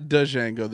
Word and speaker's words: Django: It's not Django, the D is silent.